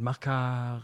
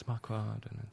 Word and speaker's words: Marquardt: and people sometimes pronounce it Marquardt, and it's Marquardt, and you're in the French Quarter.